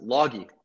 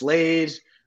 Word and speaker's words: Lage: It's so difficult, man. I all my life, nobody's been able to pronounce it. Loggy. I wouldn't expect anybody to know how to pronounce it either. It's Lage.